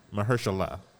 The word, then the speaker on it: Mahershala: And Mahershal, is that how you say his name? – Mahershala.